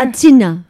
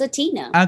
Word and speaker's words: Aitana: A-I-T-A-N-A. Anyone know how to say this? Atina? Is that Atina? How do I say this? No one has an idea here? – Atina. – Yeah, that's Atina. – Okay, so there we go. So it is Atina, Tiesto, and Katy Perry.